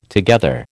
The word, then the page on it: together: American English